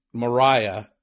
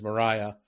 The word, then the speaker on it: Mariah: As a matter of fact, just as a fascinating side note, you know, he gives the storm a name, and he calls it Mariah, and not Maria like our sister, but he makes a point to say, no, the pronunciation is Mariah.